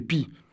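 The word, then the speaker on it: Py: Alors que ça s'écrit comme en français. Sauf qu'en occitan, on ne prononce pas le N final. Alors ça fait Pi.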